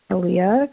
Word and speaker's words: Alliah: And they used a spelling that they developed, A-L-L-I-A-H. And Aaliyah continues to be my name.